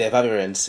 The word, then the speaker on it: Efavirenz: Efavirenz has for a long time been in use. – Did I even pronounce it right? Did I get it right? – Yes, you did. Efavirenz.